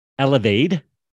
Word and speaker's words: Elevaid: So you could say co owner, marketer, uh, I don't know, business owner extraordinaire of Elevaid, which is a new a nootropic drink.